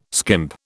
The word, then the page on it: skimp: British English